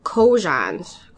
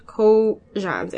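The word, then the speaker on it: Kojun: Japan has a celebration of the Empress Kojans. Kojans, if I pronounce that right. It's K-O-J-U-N, apostrophe S, birthday, which has become just as commercialized as the American Mother's Day.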